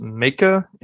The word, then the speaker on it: Maika: Starring, I think it's pronounced Maika, M-A-I-K-A, Monroe as Julia,